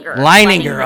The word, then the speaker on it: Leininger: It was written by local playwright Laura Leninger Campbell. Is that right? – Leininger. – Oh, I knew I'd butcher her name. – You're so close, though. – Oh, Leininger. Sorry, Laura.